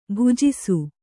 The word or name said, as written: ಭುಜಿಸು